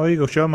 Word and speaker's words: Eaglesham: We're up at the, forgive my pronunciation, next year. It's spelled Eaglesham Beer Festival. – Oh, Eaglesham, mate.